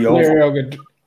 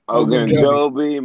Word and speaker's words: Ogunjobi: Larry Ogunjobi. – Ogunjobi, Mike Daniels, Josh Tupou.